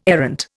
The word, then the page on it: errant: Indian